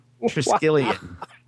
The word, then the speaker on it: Triskelion: So in the Ultimate Universe, S.H.I.E.L.D.'s headquarters, and I'm going to mispronounce it, is called the Triskelion. Oh, man, that was – Triskelion? – Triskelion.